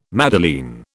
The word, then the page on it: madeleine: British English